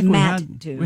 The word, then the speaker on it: Mattoon: I guess it's Mattoon. I don't know. How did you say it yesterday? – Oh, I was wondering if it was Mattoon. – Mattoon. – Yeah, it's Mattoon.